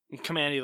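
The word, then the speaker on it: Kamandi: How do you pronounce his name? – Commandy, the Last Boy.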